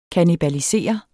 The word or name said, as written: kannibalisere